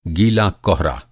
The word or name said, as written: गीला